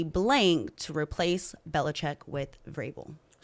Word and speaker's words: Vrabel: The big news in the NFL yesterday was the Titans firing Mike Vrabel. – That's right. – Yeah, I said it right. That V is there for no reason.